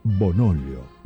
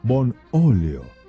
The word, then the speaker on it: Bonolio: aceite Bonolio, Bonolio, con acento en la O.